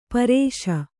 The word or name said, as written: ಪರೇಶ